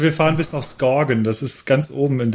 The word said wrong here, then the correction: Skagen: Es wird anders ausgesprochen. Es wird Skagen, Skagen. ausgesprochen.